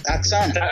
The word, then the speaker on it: Asuka: WWE's doing one thing right, because Japanese pronunciation, you never pronounce the U. Okay, but it's Asuka. – Asuka.